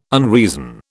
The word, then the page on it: unreason: British English